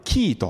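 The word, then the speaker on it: キー (Χ): で、この X とは読まないでキーと読みますけれども、この X がどこでまた用いられるかというと、クリスマスになるとイオンでもそうですけれども、クリスマスのことを X マスって書きますよね。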